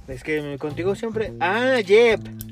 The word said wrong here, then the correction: Yelp: Yelp. Tu pronunciación es Yep.